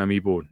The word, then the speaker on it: amiibo: Can I just say you nailed the pronunciation of Mega Man amiibo? – Yeah. – Like, holy shit. That is a mouthful.